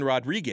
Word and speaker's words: Rodrigue: But since Jordan Rodrigue, am I saying that right? Oh, I nailed Rodrigue. – It. Rodrigue. Rodrigue. You got to roll the R's.